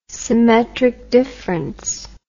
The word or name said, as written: symmetric difference